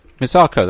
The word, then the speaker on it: Misako: Is it Misako? – Misako, that's right.